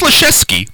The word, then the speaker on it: Leszczynski: No, it's Leszczynski. Leszczynski. – There's no S. – No, trust me, it's Leszczynski. You can call Evelyn. It's Leszczynski. – Leszczynski is the proper pronunciation, but people just slur through it. – No, no, the correct pronunciation is Leszczynski. – Not in Poland. – No, but I've talked to the family. They've set me straight on that.